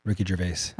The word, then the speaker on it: Ricky Gervais: Ricky Gervais.